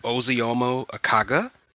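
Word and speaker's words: Ozioma Akagha: Apparently, her name is pronounced Ozeomo Akaga.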